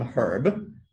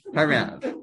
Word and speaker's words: HaRav: So in Rav Shechter's Sefer, the Nefesh HaRav, there was someone called the Rav. Other people call him Herb, but that's just pronouncing it wrong. The book is not Nefesh Herb, it's Nefesh HaRav.